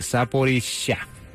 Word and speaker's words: Zaporizhia: Y a propósito, el presidente de Ucrania, Volodymyr Zelensky, aseguró que Rusia tocó fondo al bombardear la planta de energía nuclear en Zaporozhye. Voy a corregir, Zaporizhia, así se debe pronunciar.